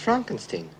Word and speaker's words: Frankenstein: Frankenstein. – You're putting me on. – No, it's pronounced Frankenstein.